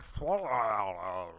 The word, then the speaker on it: Swalwell: And I'm like, it's not that difficult. It's S-W-A-L-W-E-L-L. It's Swalwell. Even though half the time I say it on this program, I'm like, Eric Swalwell.